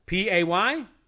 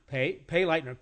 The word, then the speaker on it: Payleitner: But my name is Jay Payleitner. Hard to pronounce, hard to spell.